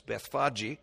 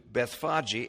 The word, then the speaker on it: Bethphage: And when they came nigh to Jerusalem, and I found that the correct pronunciation of that word is Bethphage. And so I've always called it Bethphage, but that's not correct. It's Bethphage.